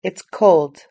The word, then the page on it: it's cold: British English